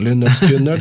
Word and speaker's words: Lynyrd Skynyrd: Jo, det heter for Lynyrd Skynyrd, ja. Hvordan den skal uttales. Husker? – Lynyrd Skynyrd. – Lynyrd Skynyrd, ok.